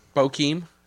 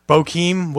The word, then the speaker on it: Bokeem: And I went with, I butcher this name every time, Brooklyn. – Bokeem. – Bokeem Woodbine.